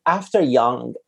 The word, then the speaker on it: After Yang: So After Yang, did I say it correctly? – Yeah.